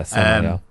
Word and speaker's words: Samael: Yeah, looks like Samuel, but elongated. – Yeah, Samael.